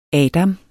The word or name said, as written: Adam